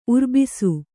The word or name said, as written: ಉರುಬಿಸು